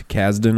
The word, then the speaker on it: Kasdan: Lawrence Kasdan, am I saying that right? – Yeah, he writes the Star Wars.